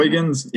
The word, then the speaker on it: Huygens: Is that how you say it? Huygens, yeah.